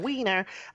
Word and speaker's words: Weiner: Weiner.